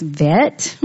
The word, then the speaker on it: Chet: All right, the number eight is, it's spelled C-H-E-T, but this morning I looked to see how you pronounce it. It's actually pronounced vet, not chet.